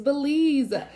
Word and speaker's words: Belize: It's Belize.